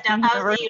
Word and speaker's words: Dalhousie: Is it Dalhousie? Is that yes, yes, Dalhousie University.